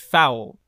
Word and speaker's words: Phoule: And it's pronounced Phoule.